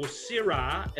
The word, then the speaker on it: Syrah: In fact, when people think of Shiraz or Syrah, as it's often pronounced by the French, the South Africans, the New Zealanders, uh, and many other parts of the world, the Californians pronounce it as Syrah, but in Australia we call it Shiraz.